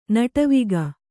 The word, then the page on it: ನಟವಿಗ: naṭaviga